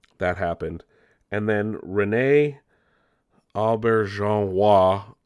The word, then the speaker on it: Auberjonois: Odo was played by René Auberjonois.